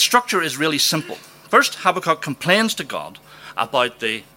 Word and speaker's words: Habakkuk: One of Jeremiah's contemporaries was a man called Habakkuk. Apparently the right way to pronounce that is Habakkuk, but that sounds so wildly pretentious I refuse to use it.